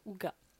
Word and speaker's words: hygge: Mais tu dois te demander euh, qu'est-ce que ce mot Ouga En réalité il s'écrit IJ, H-Y-G-G-E et ça se prononce Ouga.